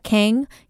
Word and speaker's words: Kang: So you can find me at Monica Kang, K-A, Monica Kang. At K-A Kang is how I pronounce my Korean name. So K-A-N-G is my last name, Monica with a C.